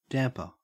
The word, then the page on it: damper: Australian English